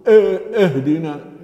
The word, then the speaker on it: اهدنا: من الخطأ أن نسمع نقول اهدنا، لا اهدنا، هذا خطأ كثير شايع، لابد من ملاحظة نطق الهمزة، هي همزة وصل، وهمزة الوصل تنطق في أول الكلمة مكسورة إيه، اهدنا اهدنا الصراط المستقيم.